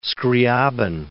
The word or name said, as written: Skryabin